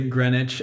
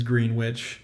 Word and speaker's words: Greenwich: And they say Greenwich, and when I read the subtitles, my brain says Greenwich. Yeah. And I'm like, nope, that's not how you say it.